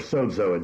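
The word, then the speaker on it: sozo (σῴζω): Spelled S-O-Z-O, we pronounce it sozo.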